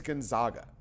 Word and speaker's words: Gonzaga: It is Gonzaga. – Yeah. How do you pronounce an A in any romance language? Ah.